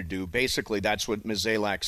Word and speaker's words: Mozeliak: Yeah, I mean, if you have a vision, if you're John Mozeliak, I think is the way you pronounce it, Mozeliak,